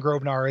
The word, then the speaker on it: Grobnar: The reason why I'm saying Grobnar is because I tend to say pronounce, you know, G-R-O-B with a long O.